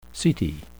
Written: setae

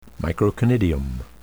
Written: microconidium

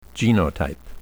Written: genotype

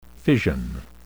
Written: fission

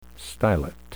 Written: stylet

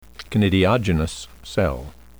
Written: conidiogenous cell

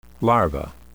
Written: larva

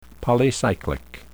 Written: polycyclic

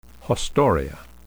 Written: haustoria

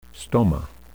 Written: stoma

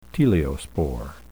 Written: teliospore

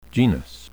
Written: genus

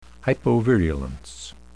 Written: hypovirulence

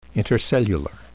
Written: intercellular

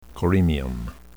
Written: coremium

